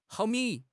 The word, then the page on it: 毫米: HSK6